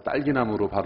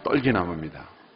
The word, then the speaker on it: 떨기나무: 어떤 분은 이 떨기나무를 잘못 발음해서 딸기나무로 발음하시는 분이 있어요. 떨기나무입니다.